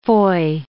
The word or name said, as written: foy